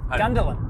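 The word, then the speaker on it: Gungahlin: Is that how you say it? – What? – Gungalin? – Gundalin. – Gungalin? – Gundalin. – Gundalin? – Gundalin. – Oh, I didn't see a D in there. Okay.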